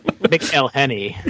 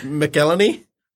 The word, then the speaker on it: McElhenney: McElhenney. – Yeah, McElhenney.